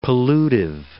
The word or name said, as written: pollutive